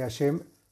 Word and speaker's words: Hashem: Y se deberá meditar el santo nombre de Hashem que se, que se pronuncia, que es Hei Shin, Mem, que se pronuncia Hashem.